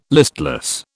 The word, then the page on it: listless: British English